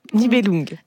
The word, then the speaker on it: Nibelung: Nibelung.